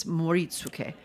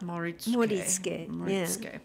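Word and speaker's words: Moritsuke (盛り付け): I always miss a syllable in Japanese words somehow. – They're pretty flat. And, yeah. And some people try to find where the accent goes, and the answer is nowhere. It's flat. – Moritsuke.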